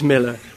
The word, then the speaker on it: Millar: No, it really is Miller. It's an A, but you pronounce it like an E.